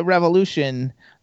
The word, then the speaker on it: Revolushn: But the important thing to remember is that Revolution is spelled R-E-V-O-L-U-S-H-N. And that's how you get Revolution.